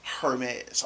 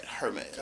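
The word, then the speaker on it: Hermès: And this is 18 more songs to the Hitler Wears Hermes 8. Or Hermes. I'm sorry. Mm-hmm. You got to say it right. Hermes. – I thought the H was silent.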